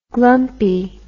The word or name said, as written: glumpy